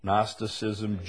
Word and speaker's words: Gnosticism: Gnosticism, G-N-O-S-T-I-C-I-S-M. Starts with a G, but the G is silent.